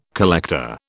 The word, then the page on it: collector: British English